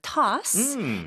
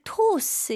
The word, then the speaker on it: toss: Toss. 근데 이거는 발음을 toss 이렇게 하지 마시고 네. – 아 이렇게 턱이 내려가는 느낌으로 toss.